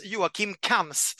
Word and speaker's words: Joakim Kams: Det uttalas Joakim Kams, så det är kul att vi redan har pratat om honom och sagt fel hela tiden i, eh, i det här programmet.